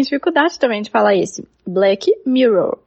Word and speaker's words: Black Mirror: Eu perguntei para a Laurinha assim, Laura, como que eu pre, como que pre, como que pronuncia Black Mirror? Porque o Black eu sei, né? Mas o Mirror. E ela falou assim, ah, se eu tiver alguma dificuldade, você vai e assim, Black Mirror.